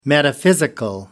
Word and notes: metaphysical: British English

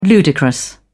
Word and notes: ludicrous: British English

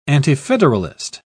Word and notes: antifederalist: British English

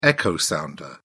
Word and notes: echo sounder: British English

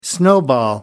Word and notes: snowball: American English

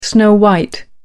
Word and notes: snow white: American English

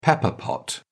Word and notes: pepper pot: British English

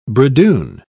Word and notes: bridoon: British English

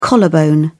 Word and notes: collarbone: British English

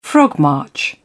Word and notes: frogmarch: British English

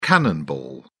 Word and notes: cannonball: British English

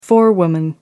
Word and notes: forewoman: American English

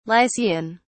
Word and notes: lycian: British English

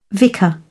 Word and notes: vicar: British English

